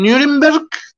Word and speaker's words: Nuremberg: Ahorita les subí una de las mejores, mejores conciertos de Aerosmith que hay, que es el Rock and Ring en el 97, en Nuremberg, creo que se pronuncia así. Dice mi productora que está bien pero que no lo vuelva a hacer.